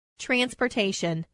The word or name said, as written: transportation